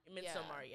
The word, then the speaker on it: Midsommar: Is it pronounced Midsommar? – Midsommar, yeah, because yeah. oh, it's like a Swedish thing.